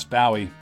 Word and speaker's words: Bowie: That's John Ross Bowie. Look him up. His last name is spelled like David Bowie, but it's pronounced like the island of Maui.